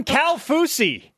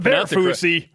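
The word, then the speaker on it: Kaufusi: Bronson Kafusi, not Bear Fusi. – That's how you pronounce it correctly.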